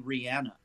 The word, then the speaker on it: Rihanna: I do believe it's actually Rihanna. – Oh, Rihanna. Okay. – Yeah, I think she pronounces it Rihanna.